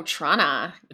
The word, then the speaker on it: Toronto: Oh, Trana. Okay. Yeah, okay, pronunciation. – That, no matter what anyone tells you guys, that's how you pronounce it.